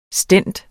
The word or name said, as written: stent